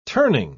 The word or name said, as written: turning